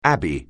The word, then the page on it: abbey: anglais